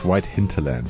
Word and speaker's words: White Hinterland: See, Mav, within 2 minutes, you've already got a name wrong. It's White Hinterland.